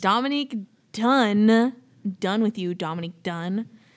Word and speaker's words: Dunne: Dominique Dune. – Dominique Dunn. – Tip. There you go. It's spelled D-U-N-N-E, okay? – Yeah, that's wrong.